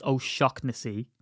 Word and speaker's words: O'Shaughnessy: And you'll say, no, it's Shocknessy. Right? Just, I looked it up. There's 10 really common Irish names that are mispronounced. Kavanaugh is Kavanagh. O'Shaughnessy is O'Shocknessy.